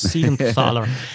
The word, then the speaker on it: Siegenthaler: Völlig richtig, also ähm, äh, Hischia und, wie wird der ausgesprochen? Ich glaube Siegenthaler, oder? Jonas Siegenthaler.